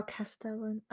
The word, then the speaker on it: Castellan: First, your spelling has Castellan rather than Castellian. So I don't know if it's a spelling mistake. – Me saying it. – And you saying it. – It's me saying it. That's how it's spelled. – Oh, Castellan. All right.